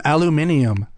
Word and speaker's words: aluminium: Why do they pronounce aluminum aluminium? – Because it's just how they pronounce those syllables. – Oh, yeah. – That's what people say. – Okay. – But here we go. Aluminum, right? – Yeah. – The last three letters are N-U-M. – Right. – Yeah. – Aluminium, N-I-U-M. They add an I.